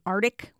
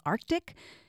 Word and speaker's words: Arctic: Like, do your fists clench when you hear somebody say Arctic when you know the correct pronunciation is Arctic?